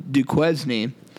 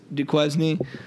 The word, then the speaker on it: Duquesne: But we were calling this team Duquesne, as Hutch so famously coined it, and it is pronounced Duquesne. Our buddy Leo reached out and corrected us.